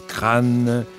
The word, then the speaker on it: Craon: La chanson de Craon qu'il faut prononcer crâne, comme les crânes qui remontent encore, toujours et toujours à la surface.